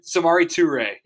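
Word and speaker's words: Samori Touré: That's interesting though, because I look at I'm going to butcher this guy's name, but Samurai Tori, Samurai Tori, oh, Samari Samari Toure, yeah.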